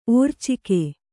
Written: ಓರ್ಚಿಕೆ

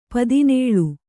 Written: ಪದಿನೇಳು

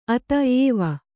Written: ಅತ ಏವ

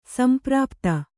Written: ಸಂಪ್ರಾಪ್ತಿ

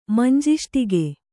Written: ಮಂಜಿಷ್ಟಿಗೆ

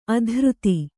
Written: ಅಧರಿತ

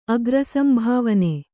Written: ಅಗ್ರಸಂಭಾವನೆ

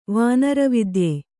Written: ವಾನರವಿದ್ಯೆ

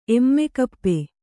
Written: ಎಮ್ಮೆಕಪ್ಪೆ

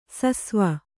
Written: ಸಿಸವೆ

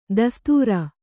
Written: ದೋಸ್ತಾರ

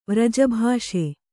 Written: ವ್ರಜಭಾಷೆ

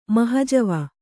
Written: ಮಹಜವ

